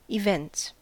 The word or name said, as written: events